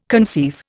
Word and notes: conceive: Indian

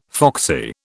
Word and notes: foxy: British English

